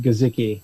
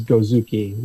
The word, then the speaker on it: Guzicki: It's actually pronounced Guzicki. – Guzicki. – Yeah, keep putting but my a U brand in there. Is Guzicki, which is has a story in itself.